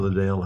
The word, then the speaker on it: Liddell: And the Eric Little, uh-huh. Liddell, how you say his name.